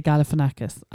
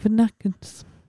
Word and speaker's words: Galifianakis: I find it challenging to say Galifianakis. I'm not even sure I'm saying it right. – I think you are. – Zach Galifianakis.